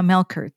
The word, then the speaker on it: Melchert: My name is Elena Subia Melchert. The CH in Melchert sounds like a K, as in Christmas.